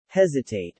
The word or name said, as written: hesitate